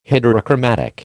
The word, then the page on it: heterochromatic: American English